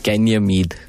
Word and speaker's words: Ganymede: Ganymede.